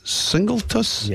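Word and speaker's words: singultus: Singletus, is that how I pronounce it? Singletus? – Yes.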